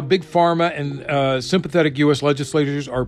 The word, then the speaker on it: PhRMA: An email from Jared McCloud, a lobbyist with the Pharmaceutical Research and Manufacturers of America, PRMA, which is it's spelled out PHRMA, so it's pharma.